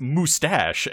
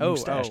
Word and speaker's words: moustache: You're mispronouncing it. It's moustache, as he usually says it. Moustache.